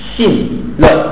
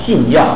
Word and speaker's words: 信乐: 佛教我们精进啊，佛教我们欢喜信乐。哎，有时候的这个也读作药，信药啊，药就是性喜称性的大欢喜，叫做信药啊。